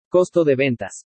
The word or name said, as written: COSTO DE VENTAS